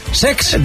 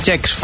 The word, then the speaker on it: Cesc: No lo he dicho mal, es Sex. – De Chex.